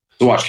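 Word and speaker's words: Saguache: It's Sagatch County, right? – Swatch County, yeah. – Swatch County. I couldn't pronounce it. I've seen it written, but never heard it.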